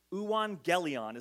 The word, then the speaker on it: euangelion: There's a little Greek word, it's not a little Greek word, a long Greek word that is translated gospel here that is the Greek word euangelion, is how you pronounce that nonsense in the middle there.